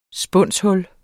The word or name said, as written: spunshul